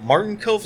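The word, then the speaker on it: Martin Kove: Because that's a fun time, because last year they had, is it Martin Cove? Is that how you pronounce his name? – Yeah.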